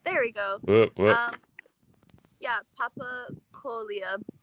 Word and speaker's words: Papakolea: With Hawaiian, you can kind of look at the word and just break it up as syllables most of the time. Not all the time, but most of the time. And so, papakolea.